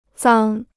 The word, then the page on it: 脏: zàng